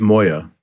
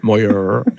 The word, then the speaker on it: Moyer: It's an R rather than a T. Moyer.